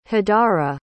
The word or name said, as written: hadara